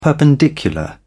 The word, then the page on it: perpendicular: British English